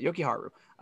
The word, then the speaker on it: Jokiharju: Oh, yeah, that's how you pronounce it, Jokiharju.